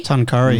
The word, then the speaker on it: Tuncurry: I'm doing a mini mental health tour through Foster and is it Tuncurry? – Tuncurry. Mm. – Thank God I pronounced that right, because honestly, I've been really struggling with that.